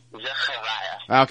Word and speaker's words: Zachariah: My actual first name in Hebrew is pronounced Zachariah.